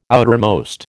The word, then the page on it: outermost: American English